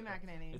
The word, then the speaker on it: McEnany: Did I say her name right? – Yes, that is her name. Okay. Kayleigh McEnany.